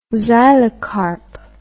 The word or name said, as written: xylocarp